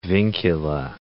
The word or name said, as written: vincula